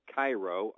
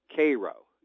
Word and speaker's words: Cairo: You say Cairo, I say Cairo.